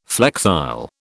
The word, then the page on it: flexile: British English